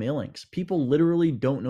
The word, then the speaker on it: Illinx: They come at me all the time. I used to get, oh, you're the guy from Linux Toys. It's like, what? No, I'm not. I'm not a computer company, actually. You're the Illinix. You're the Linux. You're the guy from Illinix. It's like, nope, none of those are correct.